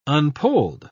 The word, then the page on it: unpolled: British English